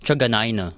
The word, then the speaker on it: Truganina: sa kanila, sa, sa, sa pagsasakay ko doon sa mga, pag may uh, private service, ganyan, Truganina.